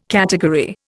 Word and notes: category: Indian

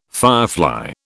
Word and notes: firefly: British English

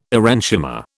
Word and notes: aerenchyma: British English

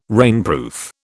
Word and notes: rainproof: British English

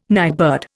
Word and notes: nightbird: Indian

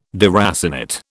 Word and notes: deracinate: British English

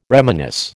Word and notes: reminisce: American English

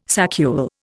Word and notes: saccule: Indian

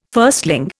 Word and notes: firstling: Indian

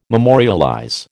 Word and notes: memorialize: American English

